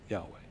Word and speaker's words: Yahweh: In Hebrew, it's his name, Yahweh, right? And as best we understand it, that's how it's pronounced, Yahweh.